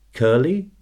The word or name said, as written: curly